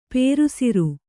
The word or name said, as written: ಪೇರುಸಿರು